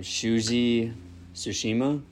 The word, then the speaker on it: Shuji Tsushima: So Dazai's real name, Shuji Tsushima.